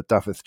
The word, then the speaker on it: Dafydd: Yeah, your pronunciation of Dafydd was much better than mine.